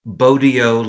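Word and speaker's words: Bogyo: One trick is Bodio like rodeo, which isn't 100% precise, but it's close enough and so much better than Bozo that I'm happy to take it.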